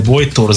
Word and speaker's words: Bojtor: Ez azért hangsúlyozom ki, mert sokan Bojtosnak értik, és nem Bojtos Zoltán, hanem Bojtor Zoltán. – Most, aki a Gépház első oldalat nem tanultanak, hogy neked Bojtor Zoltán a neved, az olyan most már fölösleges.